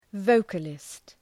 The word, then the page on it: vocalist: ‘vəʋkəlıst